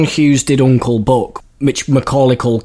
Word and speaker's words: Macaulay: Macaulay Culkin. I can't say his name. I've never been able to say his name. – Macaulay.